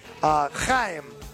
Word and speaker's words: Chaim: C-H-A-I-M. And, you know, because they're Mediterranean. You know, he's Israeli. Chaim. Chaim. Like L'Chaim? – Yes, yeah. – C-H-A-I-M. Fitz. W-I-T-Z. Gene Simmons. – Chaim Fitz.